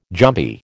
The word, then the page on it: jumpy: American English